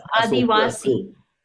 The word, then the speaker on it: Adivasi: Anyway, it's Adivasi.